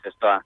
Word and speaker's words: Zestoa: ¿Venís, bueno, sois de Zestoa, lo digo bien o no? – Sí, Zestoa.